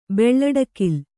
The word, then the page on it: ಬೆಳ್ಳಡಕಿಲ್: beḷḷaḍakil